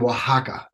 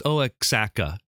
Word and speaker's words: Oaxaca: And my mother represented this really neat Mexican folk artist who does it from Oaxaca. Oaxaca is a southern region of Mexico. They do this really nice silk. – Oh, silk, I think you're pronouncing it wrong. It's Oaxaca is how you say it.